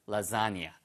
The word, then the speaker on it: lasagna: He's making some kind of lasagna, right? His own, with his own recipe. 자, 그래서 필요하다면 if necessary, 그렇죠? 예. Add more cheese. 더 추가하는 거죠. 어디 어디에다가? 그러면 to가 나와야 됩니다. To your lasagna. Lasagna. L-A-S-A-G-N-A-S.